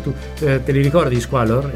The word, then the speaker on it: Squallor: Degli Squallor. – Detto bene? – Sì, sì, hai detto benissimo.